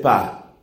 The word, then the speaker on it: pas: Y yo miro cómo está escrito y resulta que ese pa tiene P-A-S, pero la S no suena. Y la profesora me dice, no, la S no se pronuncia.